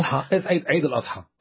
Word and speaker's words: Eid al-Adha: It's called the Festival of Adah. You've got a different pronunciation, I know. – Eid al-Adha.